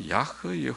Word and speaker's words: 야훼: 주 여호와라는 원어를 보니까 야훼 여호와, 여호와는 뭐 야훼 그렇게 발음도 하지만은 이제 찍어져 있는 모음자는 여호와라고 찍어놨습니다.